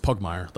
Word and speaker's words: Pugmire: I don't know why I had such a hard time with that word a couple days ago, but that was, like, it's Pugmire. Like, that's how you say it, Justin.